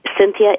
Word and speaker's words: Cynthia: Cynthia is spelled the traditional way, C Y N T H I A.